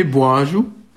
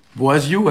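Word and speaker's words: boazioù: Donc, c'est un mot qui a un pluriel régulier, « Boaju ».« Boaziu », alors, nous, on a tendance à prononcer « J », quoi, hein, « Boaju ».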